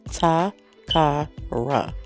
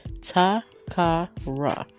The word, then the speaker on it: Takara: It is three syllables. Ta-ka-ra. Six letters. How are you mispronunciating it? How are you mispronouncing it? How is it even possible? There's no E in there. There's no I in there. It's just T-A-K-A-R-A. Ta-ka-ra. Let's say it together. Ta-ka-ra.